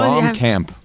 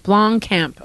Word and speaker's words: Blomkamp: I can't say his name. How Blomkamp. – Blomkamp. Okay.